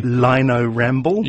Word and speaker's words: Lino Ramble: Lino Ramble. – You should probably spell that. What they wanted to do, Lino, as in Lino on the floor, and Ramble as in crawl across it, like you did when you were a kid.